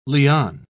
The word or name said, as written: liane